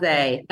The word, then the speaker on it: Masé: Mase. That's right. – Okay. – That's what anyone in Italy would call me.